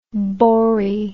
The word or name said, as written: boree